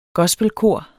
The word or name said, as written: gospelkor